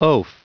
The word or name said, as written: oaf